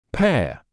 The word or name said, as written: pair